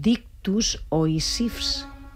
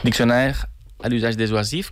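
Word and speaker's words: Dictionnaire à l'usage des oisifs: Doncs seguim escoltant una altra peça teua, que no sé si es diu Dictus Oisifs. Ho he pronunciat bé? – Diccionaire a l'usage des oisifs,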